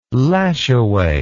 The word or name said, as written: Lajoie